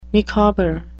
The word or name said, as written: micawber